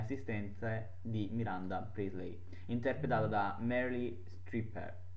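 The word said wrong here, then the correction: Streep: Strip, no, Strip. Hm, ho sbagliato a pronunciare, quindi sembrava una stripper.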